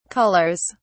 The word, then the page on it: colours: British English